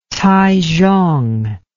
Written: taizhong

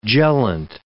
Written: gellant

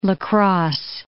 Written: La Crosse

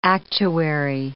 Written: actuary

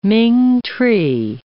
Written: ming tree

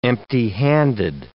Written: empty-handed

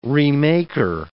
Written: remaker

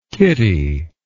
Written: kittie

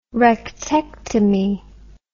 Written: rectectomy

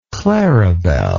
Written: claribel

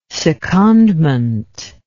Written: secondment

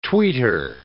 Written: tweeter

